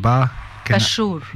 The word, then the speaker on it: Bashour: You know, we spoke today with Jeremy Rattig, now the Reem, and sorry Reem, your surname, Bashour.